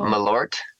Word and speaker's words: Malort: Sorry, how do you say that? – Malort. Malort.